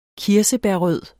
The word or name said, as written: kirsebærrød